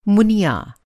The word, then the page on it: मुनिया: منیہ; Hindi